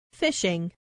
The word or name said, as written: fishing